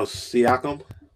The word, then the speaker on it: Siakam: Sack Sacker, I can't even say his name. Toronto Raptors trade Sacker and Sacker. Ugh. Wow. Oh, Oh, Siakam. – So, Pascal yeah. Siakam.